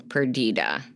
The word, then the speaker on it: Perdita: Well, and I was like, oh, Tyler, they probably wanted it to rhyme with Anita. So Perdita. Maybe.